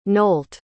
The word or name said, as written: nolt